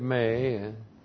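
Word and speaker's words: may (μή): Well, the negative may. M long E, not.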